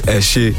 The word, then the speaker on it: Eshe: Ise part two. I didn't pronounce it right. – You tried. – What? – It's Eshe.